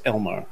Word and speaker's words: Elmo: So, just because this one makes me laugh, his name is Elmo. Emphasis on the mo.